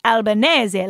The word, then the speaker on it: Albanese: you're supposed to say his name like Albanese, like Bolognese.